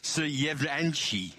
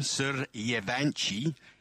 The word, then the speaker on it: Suryavanshi: Bear with me here as I try to pronounce Vaibhav Suryavanshi. Let's try that again. Suryavanshi. That's him. Suryavanshi, who's the teenage sensation playing for the Rajasthan Royals in the IPL.